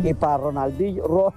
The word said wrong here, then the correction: Roldanillo: Ronaldillo. No lo puedo pronunciar. – Roldanillo.